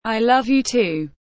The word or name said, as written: i love you too